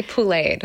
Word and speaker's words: poulet: It's to be poulet. So it's a poulet is the word for chicken in French. It's spelled P-O-U-L-E-T. So it's like to be pouleted.